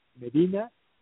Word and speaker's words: Medina: My address, my personal email, which I gladly give it to you if anyone would like to share something, or just Raul Medina. I will spell it. That's R A U L M E-D-I-N-A.